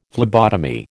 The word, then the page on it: phlebotomy: American English